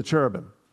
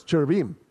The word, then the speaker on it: cherubim: I always think it sounds a little Italian, so every once in a while I drop back to the more English pronunciation, the American English of cherubim. But it's not, it's cherubim. Cherubim, if you really want it,